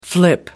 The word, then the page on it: flip: American English